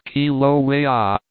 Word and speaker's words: Kilauea: that of Kilauea, pronounced Kilauea, and from thence back to San Francisco and then, doubtless, to the States.